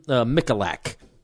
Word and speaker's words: Michalak: This guy named Stephen Michalak. That's how you pronounce his name.